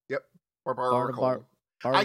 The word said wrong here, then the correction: Barbaracle: Well, it's barbaracle. Like a barbarian. – Yeah, but a barnacle.